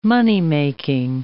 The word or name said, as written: moneymaking